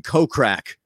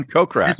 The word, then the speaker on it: Kokrak: I got Jason Kokrak. – No, that's his name, Kokrak. That's it.